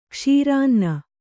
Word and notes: ಕ್ಷೀರಾನ್ನ: kannada

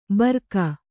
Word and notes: ಬರ್ಕ: barka

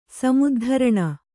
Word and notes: ಸಮುದ್ಧರಣ: samuddharaṇa

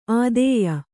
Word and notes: ಆದೇಯ: ādēya

